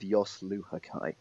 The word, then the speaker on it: Jos Luhukay: So, yes, I would say go with Jos Luhukay.